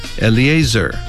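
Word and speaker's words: Eleazar: 퍼네이하스 이렇게 발음하시고 그 다음 엘르아살은 엘리에이저 엘리에이저 한번 더요. 엘리에이저 이렇게 연습해 주시기 바랍니다.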